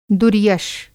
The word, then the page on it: दुर्यश: Hindi